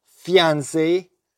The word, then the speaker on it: fiancé: Fiancé o fiancé, se pronuncian igual, para prometido o prometida.